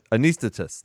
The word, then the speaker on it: Anesthetist: And then Anesthesis, I think is how you say it. Anesthesis. – Oh, Anesthetist. – Anesthetist. There you go.